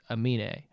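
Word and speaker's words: Aminé: And I believe he pronounces it Amine.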